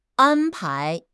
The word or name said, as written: an1 pai2